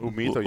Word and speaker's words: Humito: And Humito. Am I pronouncing that right? – Humito, yes.